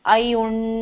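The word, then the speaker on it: अइउण्: अब दोबारा सुनिए, अयुण, उण नहीं बोला मैंने,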